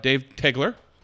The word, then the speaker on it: Dave Tegeler: He is the Concours chairman for the Ferrari Concours, Dave Tegeler.